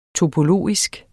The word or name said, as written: topologisk